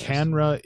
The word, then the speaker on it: CANNRA: Canra is the Cannabis Regulators Association. It's spelled Cannra, Canra.